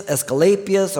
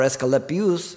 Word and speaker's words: Asclepius: And so you had the temple of Zeus, which was one of the seven wonders of the world in Olympia, Athena, Dionysius, Asclepius, or Asclepius, depending on two different pronunciations.